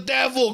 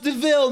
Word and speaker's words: DeVille: The only thing I found that was like weird, like the whole, hey, it's a devil car. And I was just like, it's pronounced DeVille, mate.